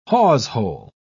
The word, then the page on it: hawsehole: British English